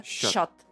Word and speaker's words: чат: Пишется чат, читается щат. Татарша. Щат.